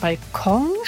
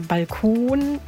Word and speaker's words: Balkon: Aber zum Beispiel beim Wort Balkon. – Ja. – Oder Balkon oder Balkon gibt es zum Beispiel all diese drei Aussprachen.